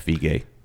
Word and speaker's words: Villeneuve: Is that how you say it? Villeneuve? – Vigé. – Denis Vigé yeah.